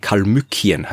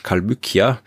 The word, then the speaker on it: Kalmykien: das sind vor allem so Regionen um ich kann das alles nicht aussprechen, Kalmykien heißt es auf Deutsch, Kalmykia.